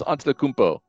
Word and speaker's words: Antetokounmpo: But Giannis Antetokounmpo.